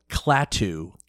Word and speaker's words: Klaatu: released an album by a band called Klaatu. – Spell it. – K-L-A-A-T-U.